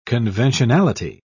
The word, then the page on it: conventionality: British English